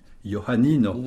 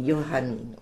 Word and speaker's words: Joannino: Voilà, le J sans le chapeau, donc que l'on prononce Joannino. – Joannino.